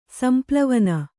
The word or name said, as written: ಸಂಪ್ಲವನ